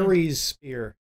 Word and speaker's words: Ares Spear: It's from their Air Spear? Have you pronounced that one? – Ares Spear. Ares Spear? – Like Ares. Ares.